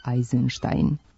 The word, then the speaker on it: Eisenstein: Međutim, malo se tako uobličenih prezimena ustalilo, pa je primenjen objedinjeni obrazac sa izvornim izgovorom, bilo da prezimena dolaze iz Rusije ili sa zapada. Zato izgovaramo Rubinstein, Bernstein, Bronstein, pa i Eisenstein.